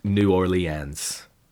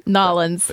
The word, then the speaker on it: New Orleans: It's not pronounced New Orleans. Like, there's a lot of different ways you can pronounce it, but New Orleans is not one of them. She didn't take. – Nolans. – Nolans. Yeah, I should have told her just say Nolans and it'll be fine.